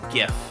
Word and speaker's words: GIF: And PSA, it is pronounced GIF.